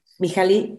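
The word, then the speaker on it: Mihály: Ajá, ¿y Mijail se dice? Mijali. Mijali, Mijali.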